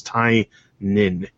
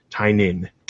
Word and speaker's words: Tynion: I'm going to pronounce his name right because he actually posted on Twitter exactly how to pronounce it. – And I was like Lenin, okay, right? – I'm gonna start to feel bad if I keep doing it now that he's actually told the world that everybody is pronouncing it wrong. It's Tynion. Tynion.